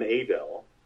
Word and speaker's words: Adel: But we did the first gig at a place called the Ebony Club in Adel. That's how the locals say it, Adel, you know.